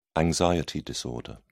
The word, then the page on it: anxiety disorder: British English